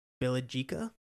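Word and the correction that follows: Bjelica: How do you pronounce his name? I don't even know. – Bielitsa. – Yeah, that guy. – Bielitsa.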